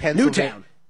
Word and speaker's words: Newtown: Newton Square, Pennsylvania. I feel like Newtown. – Oh, is it really? It's Newtown? – Yeah.